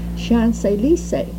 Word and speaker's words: Champs-Élysées: The most beautiful avenue in Paris, Champs-Élysées, pronounced Champs-Élysées, which means the fields of paradise.